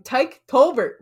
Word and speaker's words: Tyke Tolbert: And now moving on to another name that I may you know struggle pronouncing. Tyke Tolbert will be coming back as wide receiver coach. Yes, I pronounced it right.